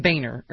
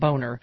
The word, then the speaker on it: Boehner: And there's a cartoon of him talking to the president, and he said it's pronounced Boehner, or not Boner.